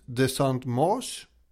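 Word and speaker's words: de Saint-Mars: Han har ju då antecknat i sin dagbok att den 8 september så anlände Bastiljens nya kommandant, de Saint-Marche. Jag tittar alltid på det efter ett franskt uttal och vill ha någon form av bekräftelse att det inte är katastrof. – Ja, men jag ger det godkänt.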